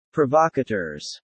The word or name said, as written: provocateurs